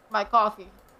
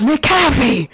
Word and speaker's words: McAfee: My coffee! – I do pronounce it McAfee. – Why? – I've always done it and I don't know how to stop. – It's McAfee! – Help! I can't stop. – Say Mick.